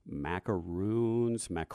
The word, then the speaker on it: macaroon: And I learned last year, maybe you can help me with this, Sam makes macaroons. Do you know the pronunciation for that? – I've always pronounced it macaroon. – Macaroon. Okay. – If you're French, it's probably wrong.